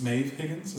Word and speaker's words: Maeve Higgins: It's about a woman named Rose, played by, I guess, Maeve Higgins.